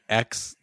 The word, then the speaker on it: Aix: I just Googled how to pronounce it. It's closer to, like, X than it is Axe.